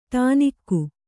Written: ಡೊಣ್ಣೆಗ

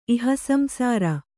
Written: ಇಹಸಂಸಾರ

